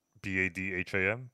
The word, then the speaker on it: Badham: I don't know how to pronounce it. B A D H A M, Badham, Badham. – That sounds right.